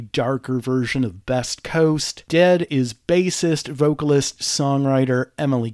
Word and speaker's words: Dehd: So I hung out in the tent for a bit and watched Dead, and that's spelled D-E-H-D.